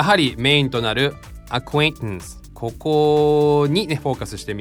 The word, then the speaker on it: acquaintance: そしてあともう一個ね、教えておくとね、この N と母音に T が挟まれてるでしょ。その後にまた N が来てるとややこしいんだけど、この T ね、 N を飲み込むように発音すると相当発音こなれてるよ。こんな感じ、 acquaintance acquaintance。